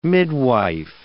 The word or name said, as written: midwife